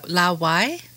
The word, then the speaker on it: 老外: La Wai. – You got it. Oh, that's exactly the right pronunciation.